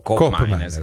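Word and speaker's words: Koopmeiners: Qui parliamo fiammingo, che è l'olandese, per la cronaca colgo l'occasione per dire che di Cop Miners, la doppia O di Cop Miners invece si legge U.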